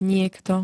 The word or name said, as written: niekto